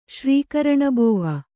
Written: ಶ್ರೀಕರಣಬೋವ

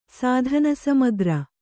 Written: ಸಾಧನಸಮಗ್ರ

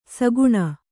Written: ಸಾಕಣೆ

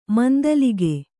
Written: ಮಂದಲಿಗೆ

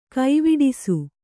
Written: ಕವಡಿಸು

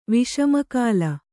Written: ವಿಷಮಕಾಲ

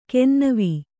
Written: ಕೆನ್ನವೀ

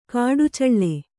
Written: ಕಡಚ್ಚಿಲು

